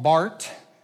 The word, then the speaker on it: Barth: His name was Karl Barth. It's spelled Barth, but it's pronounced Bart, just like Luther is actually pronounced Luther, in the same kind of that same thing.